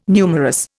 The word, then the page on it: numerous: Indian